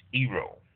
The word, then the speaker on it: gyro: That's G-Y-R-O, pronounced Gyro, for those of you who see it and always pronounce it Gyro. It's actually Gyro.